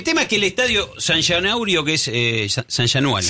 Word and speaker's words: São Januário: No es bueno mi portugués. São Januário. – Januário. – São Januário. Ahí está.